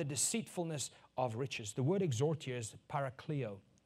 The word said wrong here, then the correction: parakaleo: Parakaleo. Parakaleo. Kristen? Don't worry about it. She says, you didn't pronounce it right. It's parakaleo.